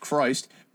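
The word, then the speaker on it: Kryst: Christ the Conqueror, pronounced Christ, was an American Christian metal project formed in August 87 by two former members of the horror punk band The Misfits.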